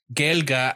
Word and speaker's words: Gaeilge: You can actually pronounce Gaeilge as something that sounds very close to Gaelic when you're speaking in the Ulster dialect of Irish.